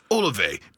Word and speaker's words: Olave: Let's go with Chris Olave. That's how you pronounce it. – Yes. – A-O-O-A.